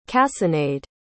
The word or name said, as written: cassonade